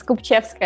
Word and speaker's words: Kopivcheska: Olga Kopivcheska, I think I pronounced that right. Welcome to Crypto 101. – Thank you. Almost there. – Tell me, come on, you, the way you said it before sounded so beautiful, and then I just butchered it. – It's Kopivcheska, yeah. It's Russian, so...